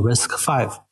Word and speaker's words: RISC-V: 以及呃，为什么它最近获得这么多关注？RISC-V 的话，它是一个精简的指令集计算机架构。它跟那个我们传统意义上讲的这个 x 八六和 ARM 是有一些差别的。它是 RISC 这个指令集的第五代，我们把它读作 RISC-V。